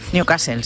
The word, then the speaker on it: Newcastle: Continúa el goteo de focos de la enfermedad de Newcastle en, lo he dicho bien. – Newcastle, sí, dices. – Bueno, o medio bien, en explotaciones avícolas de Valladolid.